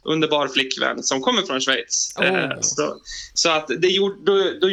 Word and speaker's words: Schweiz: Schweiz. – Ja, Gustav Fridolin hade ju lite problem med det för några år sedan, men det stämmer. Schweiz.